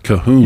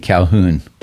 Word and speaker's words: Colquhoun: John, and then his last name is C-O-L-Q-U-H-O-U-N. And that is John. Go ahead. – Calhoun. – Yeah, I think you can even pronounce the L depending on where, so it would be Calhoun. – Yeah, so you just have lots of letters in a name that you just don't say.